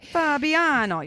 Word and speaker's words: Fabiano: Okay. Fabiano. You have to say it like that.